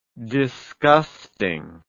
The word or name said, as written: disgusting